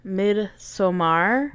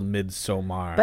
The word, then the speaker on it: Midsommar: And then we also watched Midsommar. Is it Midsommar? – I mean, it's spelled Midsommar. – But every time they say Midsommar in the movie, it's Midsommar. – Okay, maybe it's Midsommar.